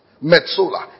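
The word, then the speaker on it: metsola (מְצוֹלָה): The word metsola, the depths, depth. Pronounce the word depths, metsola, M-E-T-S-O-L-A-H.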